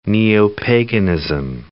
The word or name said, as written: neo-paganism